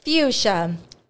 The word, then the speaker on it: fuchsia: Fuchsia. F-U-C-H-S-I-A. – Be all the syllables in there. A-E-I-O-U. – That was all hard. Fuchsia. – Dude, I would have never imagined there being a C-H in there.